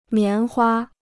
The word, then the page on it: 棉花: mián hua